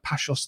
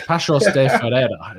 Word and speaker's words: Paços de Ferreira: Tell me, Pablo, that was probably a horrific pronunciation, but it's Paços, is it? Because you pronounce the C with the little accent on the bottom with like a hard sound. It's not like a shell.